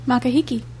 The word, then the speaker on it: Makahiki: Makahiki. Isn't that how you say it? – I don't know. – How do you say that? – Makahiki.